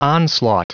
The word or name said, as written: onslaught